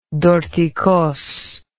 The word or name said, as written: dorticos